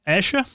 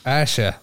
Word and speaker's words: Ayrshire: And uh, I- I'm going to say this wrong, aren't I? Oranshire? Ayrshire? Ayrshire, that's it.